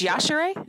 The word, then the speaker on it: Yashere: Isn't it Yashere? – No, it's Yashere. – It's Yashere? – Yeah. – Oh, shit. I've been saying it wrong for years.